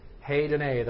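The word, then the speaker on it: hēdonē: Heidene. Those long E's in the Greek are pronounced A, like A. Like a hoser, like that. So heidene, that's how that's pronounced.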